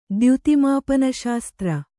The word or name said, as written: ದ್ಯುತಿಮಾಪನಶಾಸ್ತ್ರ